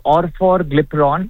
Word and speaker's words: Orforglipron: Orforglipron? – So the medicine inside is called Orforglipron. And the medicine name is Fondayo. – Fondayo? – Yeah, Fondayo. Fondayo. And the inside medicine is called Orforglipron.